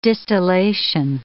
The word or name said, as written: distillation